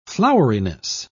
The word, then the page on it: floweriness: British English